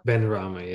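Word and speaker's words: Benrahma: I don't want to say his name wrong. Said Benarama. – Benarama, yeah.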